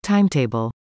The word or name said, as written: timetable